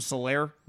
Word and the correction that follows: Clyde Edwards-Helaire: the little running back. – Clyde Edwards-Helaire. – Yeah, Clyde Edwards-Helaire.